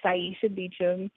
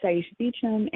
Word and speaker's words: Saisha Beecham: But my Twitter is at Saisha Beecham.